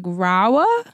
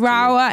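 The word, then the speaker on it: Rahua: I hope I'm saying it right because it's spelled R-A-H-U-A. I think it's like Rawa. – Yeah, that sounds right? – About right. – Rawa.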